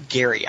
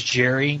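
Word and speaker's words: Gerry: Is it pronounced Jerry or Gary? I've never been clear on that. – I said Jerry. – I think it's Jerry, but I have seen guys say Gary before.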